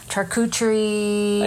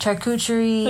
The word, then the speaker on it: charcuterie: I know, but like sandwiches, charcuterie. – I'm sorry, what is that word? – Charcuterie.